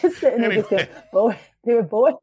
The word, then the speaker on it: Bowie: Wait, how do you say it? – Bowie. – David Bowie. Oh, it is David Bowie. Shit, now I've said it, it sounds better. David Bowie. Yeah, it's David Bowie.